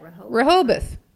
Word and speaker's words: Rehoboth: Rehoboth.